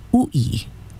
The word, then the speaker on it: u'i: But u'i is spelled u okina I, and it is pronounced u'i, u'i.